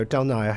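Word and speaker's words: Del Naja: Well, this desire to connect seems to resonate with something your collaborator, Robert DiNaja. Is that saying it right, DiNaja? – No, Del Naya. He'll kill me if I don't tell you.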